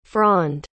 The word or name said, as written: fronde